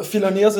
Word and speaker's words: Philoneos: Man merkt schon, ja, Philoneos. Ja, servus zusammen. – Philoneos ist schwierig auszusprechen. – Habe ich es falsch ausgesprochen? – Nö, die Leute stolpern oft drüber.